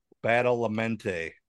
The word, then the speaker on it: Badalamenti: B-A-L-A-M-E-N-T-I. Badalamenti.